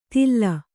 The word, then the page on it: ತಿಲ್ಲ: Kannada